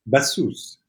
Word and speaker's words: Bassous: Is it Bassous or Bassous? Do we pronounce the S? – Bassous.